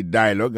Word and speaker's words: dialogue: Daily dialogue, alors, il y a pesé plus sous premier syllabe là. Daily dialogue. Alors, accent tombé sous premier syllabe là.